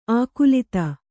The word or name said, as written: ಆಕುಲಿತ